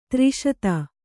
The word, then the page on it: ದೃಷದ: dřṣada